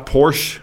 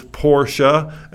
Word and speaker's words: Porsche: It's not Porsche. I've heard people who should know better call it Porsche, but it's Porsche. And the Germans just, once again, go absolutely crazy when you mispronounce it. In Germany, it's P-O-R-S-C-H-E, and the E is not silent. You pronounce the E.